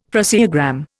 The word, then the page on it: phraseogram: Indian